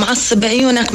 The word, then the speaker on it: عصب عيونك: Is it عصب عيونك? Am I pronouncing it correctly? – نعم عصب عيونك معناتها غمض عيونك.